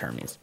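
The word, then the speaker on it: Hermès: Actually, I think they call it Hermes or something like that. They call it different than what we say. – Hermes? – Yeah, something like that. I could be wrong. It's like the beginning pronunciation is like weird.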